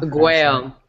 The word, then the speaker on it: Aguayo: And I'm of course talking about Roberto Aguayo. How do you say his name? I cannot. – Aguayo.